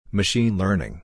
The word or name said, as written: Machine Learning